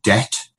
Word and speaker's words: debt: To take an example, we spell the word, I owe you some money, debt, D-E-B-T, but we don't pronounce the B.